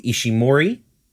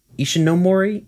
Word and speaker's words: Ishinomori: It is based on the original Kamen Rider by Shotaro Ishimori. Ishinomori.